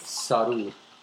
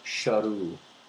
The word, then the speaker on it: Sheru: And also, he was also mispronouncing his own name, which was not Saru, but Sharu, which is S-H-E-R-U, which means lion in Hindi.